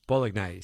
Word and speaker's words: bolognese: Chutney in your spaghetti bolognese. One of you has taken the time to write in and very cheerily said, bolognese, say it properly. Bold, not bog.